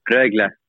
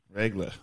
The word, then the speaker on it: Rögle: Yeah, it's always good, Rögle. – Rögle, okay. – Rögle. But it's different when we say it here in Sweden. It's Rögle. – Rögle.